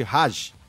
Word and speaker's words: Hajj: É o que eles chamam de Haji. É o que eles chamam de Hajj. H-A-J-J. Minha pronúncia árabe pode estar um pouco enferrujada.